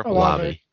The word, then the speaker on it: Olave: But Chris Olave, how do you say his name? Whatever. – Olave.